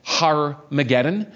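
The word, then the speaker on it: Harmagedon: We saw that in English it would be pronounced Har-mageddon,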